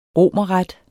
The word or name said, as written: romerret